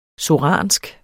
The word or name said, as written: soransk